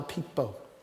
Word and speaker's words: Piippo: Here's how you spell P-I-I-P-P-O. No, yeah, P-I-I-P-P-O, pronounced John Pipo.